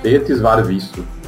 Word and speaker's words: Tētis var visu: Tētis var visu. Isso mesmo. Praticamente se vê como escreve.